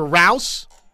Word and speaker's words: Rouse: The Oklahoma Sooners, if you missed it, they flipped the commitment for one former Stanford offensive tackle, transfer offensive tackle, Walter Rouse, rhymes with house, which, oh, by the way, is a fitting little nursery rhyme for an offensive tackle.